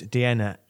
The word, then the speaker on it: Deanna: Then the audience were told that the next minister who was due to be speaking, Levelling Up Minister Deanna Davison, couldn't attend either. – Oh. Now, I heard about that. I didn't go either. They played a video from the MP, Deanna Davidson rhymes with Vienna.